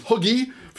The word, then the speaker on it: hygge: Your pronunciation of hygge is getting worse and worse. – We'll start with huggy.